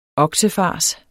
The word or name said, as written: oksefars